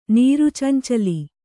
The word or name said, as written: ನೀರುಚೆಂಚಲಿ